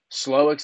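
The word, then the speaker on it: Slowik: There's only three coaching spots left, and you have Slowick's available. I don't know how to pronounce his name. He's the coordinator for the Texans. – Yeah, you got right? – it. You got it, Slowick.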